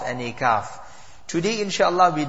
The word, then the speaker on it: kaf: Last week we had discussed the difference between the qaf and the kaf. So kaf was easy recited, free-flowing kaf as compared to qaf, and that would is pronounced with a full mouth.